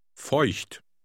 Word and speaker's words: Feucht: It contains the letter combination E-U. In German, this is pronounced Oi, Oi. – Try reading Word Number 1 out loud. – Feucht.